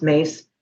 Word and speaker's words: Myss: Um, M Y S S S, M Y S S, spelled, but it's pronounced Mace.